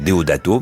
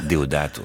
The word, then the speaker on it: Deodato: Moi, j'allais dire Deodato, mais Deodato.